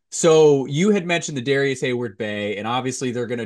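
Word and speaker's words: Darius Heyward-Bey: Although Darius Hayward Bay didn't kill anyone. – That's right. Yeah, there's the right way to say it.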